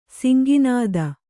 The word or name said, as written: ಸಿಂಗನಾದ